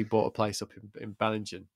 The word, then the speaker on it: Bellingen: So, you know, I'm like, but they just moved to Bellingen. Is that how you say it? – Oh, yeah. Yeah, Bellingen.